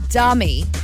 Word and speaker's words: Demi: Now, you read her name, spelled D-E-M-I. – Mm-hmm. – Demi, right? – Mm-hmm. – That's how it's pronounced. – Yep. – No, it's not. – How do you say her name? – Dummy. – Dummy Moore.